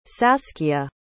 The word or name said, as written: saskia